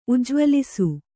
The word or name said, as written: ಉಜ್ಜ್ವಲಿಸು